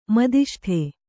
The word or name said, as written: ಮುದಿಷ್ಟ